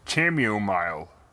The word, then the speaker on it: chamomile: And Dale finds some chamomile tea and he... – I believe it's pronounced chamomile. – Actually, it's... Chamomile is the Greek pronunciation of that.